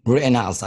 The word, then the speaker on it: Rittenhouse: Rittenhouse. – Rittenhouse? – Yeah. – Rittenhouse.